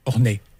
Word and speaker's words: Ornex: C'est ça Orné. – Orné, on dit pas le X.